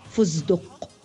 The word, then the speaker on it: فستق: وقد ينطقونها دالا في كلمة فستق فيقولون فزدق محولين التاء إلى دال والسين إلى زاي